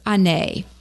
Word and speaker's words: Hannett: Well, it's pronounced Anay. – Okay. – And it looks like Hannett. – Uh-huh. – So I tell everybody in Louisiana that works so well, just think of A-Bear. – Yeah. – Because it's silent. – Silent H. – Uh-huh. – And solid T.